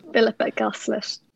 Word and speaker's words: Philippa Goslett: And Philippa Goslett, is that how I say her name? Philippa Goslett? – Philippa Goslett.